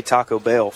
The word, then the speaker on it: Taco Bell: Well, we're going to have to teach you how to say Taco Bell first. – How do you say it? – I mean, you got to pause a little bit more. Taco Bell, get a little space in there.